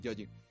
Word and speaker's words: Joji: Y esto viene de la forma en que pronuncian su nombre en, japonés, que es eh, George, pues en japonés se pronuncia como Yoji o similar, ¿no? Entonces por eso se puso así, Yoji.